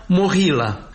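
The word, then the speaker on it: Mohila (Могила): Îi spunem Movilă, din familia Movileștilor, domni ai țării românești și Moldovei, însă Ucraina l-a cunoscut și îl cunoaște ca Moghilă, pronunțat Mohila.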